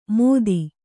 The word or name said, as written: ಮೋದಿ